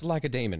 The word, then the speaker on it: Lacedaemon: I'm pretty sure it's Lacedaemon because Damon well, it is came like from Laconia.